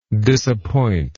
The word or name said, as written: disappointer